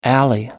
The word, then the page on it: alley: American English